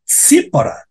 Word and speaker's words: Tsipora: para alguns comentaristas, seria seria uma segunda esposa, mas na verdade é a própria Zípora, esse lindo nome, que se pronuncia como TS. Cípora, Cípora, T-S-I-P-O-R-A.